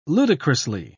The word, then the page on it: ludicrously: British English